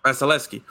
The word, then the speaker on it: Vasilevskiy: Vasilevskiy.